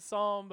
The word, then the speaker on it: psalm: And you don't pronounce the P in the word psalm, but we thought we'd have some fun with it.